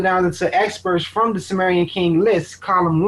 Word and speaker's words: Sumerian: It's spelled Smerian. – Sumerian, yeah. – the Sumerian king.